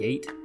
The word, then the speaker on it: Yate: I think that's how you say it, Yate. Like, yeet.